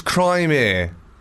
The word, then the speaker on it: Crimea: It's Crimea.